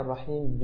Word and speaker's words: الرحيم: بالنسبة للرحيم، كلمة الرحيم لم تنطق الحاء فيها كما نطقت في الأولى، فلقد قلت الأولى بالرحمن، وهذا نطق جيد لحرف الحاء، فعليك أن تكرر حاء الرحيم بمثل ما كررت حاء، بمثل ما نطقت حاء الرحمن، فقل على بركة الله الرحمن الرحيم.